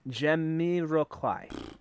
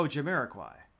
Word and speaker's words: Jamiroquai: Jamiroquai. – Oh, Jamiroquai.